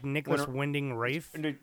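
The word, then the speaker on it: Nicolas Winding Refn: Is when I never say his name right, but it's like Windref. – Oh no, like Nicholas Winding Rafe.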